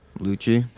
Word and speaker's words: Lucci: Lucci. – How do you spell that? – L-U-C-C-I.